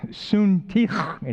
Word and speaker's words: Syntyche: I urge Euodia and I urge Syntyche, is how you say that.